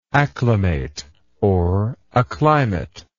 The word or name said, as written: acclimatable